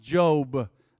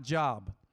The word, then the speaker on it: Job: It's pronounced Job, not Job.